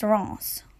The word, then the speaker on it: Reims: For locals, Reims is pronounced Reims.